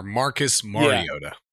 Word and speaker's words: Marcus Mariota: Remember, it's Mar-Mar, Marcus Mariota.